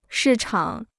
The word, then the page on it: 市场: shì chǎng